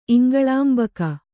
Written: ಇಂಗಳಾಂಬಕ

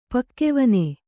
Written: ಪಕ್ಕೆವನೆ

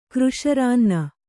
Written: ಕೃಶರಾನ್ನ

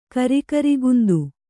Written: ಕರಿಗರಿಗಂದು

